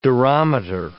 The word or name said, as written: durometer